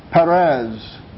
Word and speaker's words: Perez: Interesting, the word outbreak is from the Hebrew word that is pronounced Perez in English.